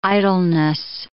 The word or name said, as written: idleness